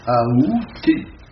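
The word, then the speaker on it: aoûté: Hein, la prononciation qui est considérée comme correcte, c'est la prononciation avec A. Aouté.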